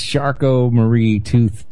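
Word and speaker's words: Charcot-Marie-Tooth: Is that how it's pronounced? I thought it was like Charcot-ree tooth or something. – That's how Ben says it. – That's how it's spelled. – It's Charcot-Marie tooth disease.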